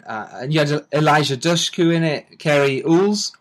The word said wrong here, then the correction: Elwes: I pronounce it in the UK Ools, but it might not be. – Yeah, El- Cary Elwes, we pronounce it Elways, yeah.